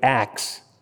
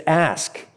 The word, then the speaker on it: ask: I know sometimes people will pronounce ask with axe. I ax them, A-X. But it's ask, A-S-K.